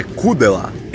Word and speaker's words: Kudela: Que não é assim que se pronuncia, é Kudela. Kudela. Kudela. Ah, opa, que... Dá acento no U.